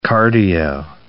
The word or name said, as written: cardio